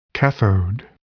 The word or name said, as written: cathode